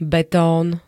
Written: betón

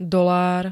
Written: dolár

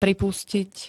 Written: pripustit

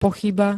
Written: pochyba

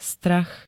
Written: strach

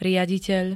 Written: riaditeľ

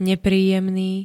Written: nepríjemný

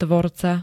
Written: tvorca

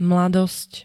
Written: mladosť

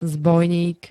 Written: zbojník

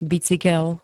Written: bicykel

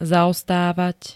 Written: zaostávať

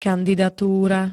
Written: kandidatúra

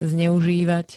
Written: zneuzivat

